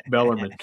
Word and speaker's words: Bellarmine: Again, Kyle Walker, I said it right, Bellarmine.